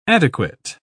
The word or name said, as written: adequate